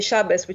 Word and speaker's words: Shabbos: So there'll be people who say Shabbos, which is the Yiddish pronunciation, and then people who will say Shabbat, which is the modern Hebrew pronunciation.